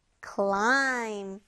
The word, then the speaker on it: climb: Climb. 자, climb 하면서 선생님이 마지막 이 알파벳은 발음이 되지 않아요 했었는데, B로 끝나죠. Climb, 오르다 라는 뜻이었습니다.